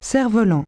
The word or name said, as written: kite